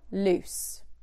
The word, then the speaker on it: loose: Lose, loose. Loose means something is not tight. It's an adjective. Lose is the verb that we're going to be talking about today. So be wary of the pronunciation and be very wary of the spelling. Lose, not loose.